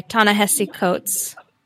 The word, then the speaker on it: Ta-Nehisi Coates: So today we are jumping back into the second section of The Water Dancer by Ta-Nehisi Coates.